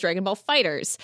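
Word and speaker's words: Dragon Ball FighterZ: No Scope Radio says, apparently we're supposed to pronounce Dragon Fighter Z as Dragon Ball, or Dragon Ball Fighter Z as Dragon Ball Fighters. What else should come with a silent Z? So Dragon Ball Fighters, the game, they have fighters with a capital Z on the end. Why? Anyway, it's not pronounced Dragon Ball Fighter Z now.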